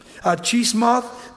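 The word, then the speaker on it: Chemosh: Chismoth, that's how it's pronounced.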